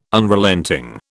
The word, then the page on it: Unrelenting: British English